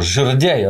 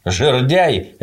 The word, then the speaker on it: жердяй: Слово «жердяй» – оно от слова «жердь». Жердяй – это высокий и худой. Не «жердяй», а «жердяй», да.